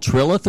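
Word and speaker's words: Trilith: This town is called, the town is actually called Trillith or something like that. Is that what it's called? Did I say that right? – Yes, sir. Yes, sir.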